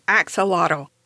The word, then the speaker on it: axolotl: Axolotl isn't the way it's pronounced in its native country of Mexico, since it comes from the name of an Aztec god of fire and lightning, but it's the common pronunciation in English, so I'm going to stick with that one.